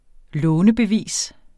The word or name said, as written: lånebevis